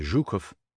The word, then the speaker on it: Zhukov: Zh, as in Zhukov, sounds like GE in Rouge.